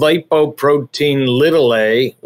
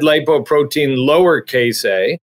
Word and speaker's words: lipoprotein(a): It's called lipoprotein little a, which also could be said lipoprotein lowercase a, because before it was discovered, a cholesterol particle that actually has a capital A was already in the scientific literature.